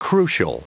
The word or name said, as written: crucial